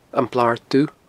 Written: Blar Dubh

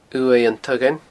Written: uaimh an taghain

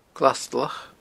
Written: glastulach

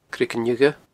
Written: Creagan Fhiodha

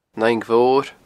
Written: An Fhaing Mhor

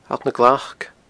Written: Allt nan Glac

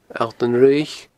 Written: Allt an Fhraoich

